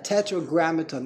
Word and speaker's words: tetragrammaton: In the art scroll, when English is a real fancy word, tetragrammaton. Is that how you pronounce it? – Tetra means four. – Yeah. Right, tetragrammaton, that's the English translation, so they're afraid to translate.